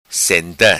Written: Senta

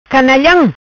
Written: Canalham

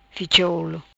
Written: Ficha Olo

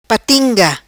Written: Patinga

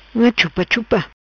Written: Chupachupa

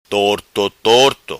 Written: Torto Torto

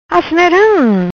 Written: Asneram

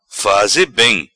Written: Faze Bem

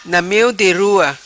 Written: Na Meo Di Rua

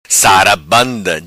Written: Sarabanda

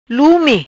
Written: Lumi

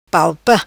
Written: palpa

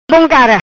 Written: Cara bom

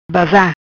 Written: Bazar

